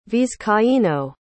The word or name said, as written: vizcaino